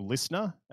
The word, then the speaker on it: LISTNR: If you're international, the best place will be to download our company's app, which is called Listener. That's spelled LISTNR.